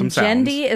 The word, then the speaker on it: Genndy: And Jendy is spelled G-E-N-N-D-Y.